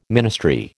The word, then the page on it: ministry: American English